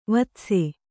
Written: ವೀತಾಸು

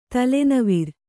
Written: ತಲೆನವಿರ್

